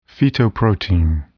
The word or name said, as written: fetoprotein